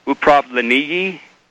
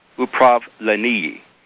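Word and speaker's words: upravleniye (управление): The two Russian words are upravleniye, and it's spelled U-P-R-A-V as in Victor, L-E-N as in Nancy, I-Y-E, upravleniye. And the final word is obushini. That means steering, by the way, upravleniye.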